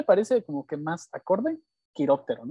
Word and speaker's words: quiróptero: que sí, sí lo dije bien, quiróptero.